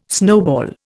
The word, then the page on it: snowball: Indian